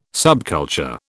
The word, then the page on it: subculture: British English